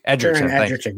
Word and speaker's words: Egerton: Taron Egerton, is that how you say his name? Egerton. – Taron Egerton, yeah.